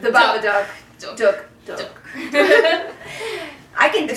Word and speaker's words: Babadook: But anyway, the Babadook. – Duck. – Because it rhymes with book and look is...